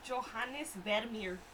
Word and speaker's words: Johannes Vermeer: His name is Johannes Vermeer. – Okay. – Your pronunciation, A plus.